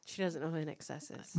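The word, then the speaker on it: INXS: I plainly remember calling them Inks. In excess.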